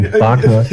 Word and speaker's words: Wagner: I, I always pronounce it in German. – Wagner. it should be. It should be that.